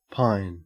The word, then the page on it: pine: Australian English